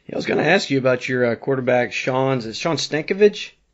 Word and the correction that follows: Stankavage: Am I saying that right? – Stankavage. – Stankavage. Okay. That's a really hard name.